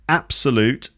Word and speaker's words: absolute: So absolute and generally, they're both stressed on the first syllable.